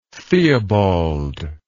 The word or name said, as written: theobald